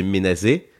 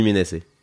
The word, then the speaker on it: Ménasé: On devrait le prononcer menacé. Mmh. Mais en fait, j'arrive pas. Donc, je dis menacé.